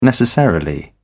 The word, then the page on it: necessarily: British English